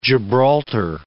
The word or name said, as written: Gibraltar